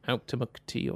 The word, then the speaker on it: Auchtermuchty: I'll go again for the literal pronunciation. Auchtermuchty. Okay, Auchtermuchty or Tai or... – But that's pretty much how you actually pronounce it.